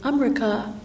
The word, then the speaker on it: Amrika: And I said, "Amrika, Amrika," is how they pronounce it.